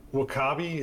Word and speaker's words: W'Kabi: I mean, I think I think if if um, W'Kabi is how you say his name.